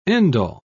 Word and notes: end-all: British English

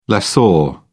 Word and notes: lessor: British English

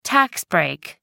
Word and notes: tax break: British English

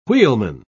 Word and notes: wheelman: British English

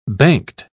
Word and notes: banked: British English

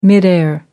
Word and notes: midair: British English

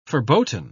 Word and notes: verboten: British English

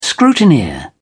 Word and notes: scrutineer: British English

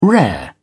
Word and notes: rare: British English

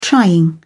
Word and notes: trying: British English

